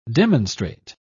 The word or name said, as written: demonstrate